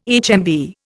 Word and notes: HMV: Indian

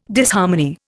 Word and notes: Disharmony: Indian

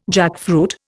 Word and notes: jackfruit: Indian

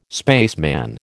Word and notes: spaceman: American English

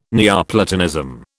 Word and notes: Neoplatonism: British English

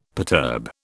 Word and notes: perturb: British English

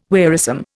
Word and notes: wearisome: Indian

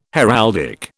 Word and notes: heraldic: British English

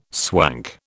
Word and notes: swank: British English

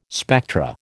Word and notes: spectra: American English

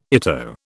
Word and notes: ITO: British English

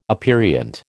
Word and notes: aperient: American English

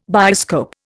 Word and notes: bioscope: Indian

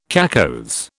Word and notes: cacoethes: British English

